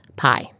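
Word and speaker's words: pai: The Maori, the indigenous people of New Zealand, have a term meaning good, which is pronounced pie.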